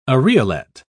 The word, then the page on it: areolet: British English